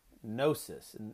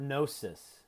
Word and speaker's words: gnosis: Gnosticism, the word comes from the Greek word gnosis, and these all have a silent G at the beginning. So the Greek word gnosis, G-N-O-S-I-S, which means knowledge.